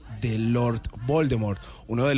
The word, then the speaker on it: Voldemort: Aquí es Voldemort. Sí, digo, Voldemort. Aquí no me lo vienen a pronunciar Voldemort. No, Voldemort. Pero Voldemort me será como Aldemar.